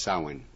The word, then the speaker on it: Samhain: It's actually pronounced Samhain.